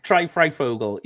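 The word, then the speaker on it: Fryfogle: Same with how do you pronounce it? Ty Freifogel? – Yeah, go with that. Ty Freifogel.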